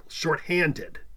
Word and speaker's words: shorthanded: Shorthanded is a trisyllabic adjective. That's a compound word stressed on the second syllable, shorthanded.